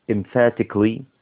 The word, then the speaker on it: emphatically: emphatically emphatically ph هنا تنطق k f s ph تنطق f خلاص كده طيب والسي اللي في وسط الكلام بتنطق k فاسمها